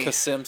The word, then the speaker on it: Qasem: Qasem Soleimani.